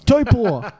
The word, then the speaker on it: Taupo: Auckland, we're in Taupo, Hamilton, probably pronouncing like Taupo wrong, but whatever. – Yeah, it's probably people that hate you right now. – It's Taupo. Yeah.